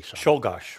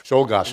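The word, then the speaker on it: Czolgosz: the McKinleys and Shoglash. How do you say Shulgash. – Shulgash.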